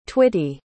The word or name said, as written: twitty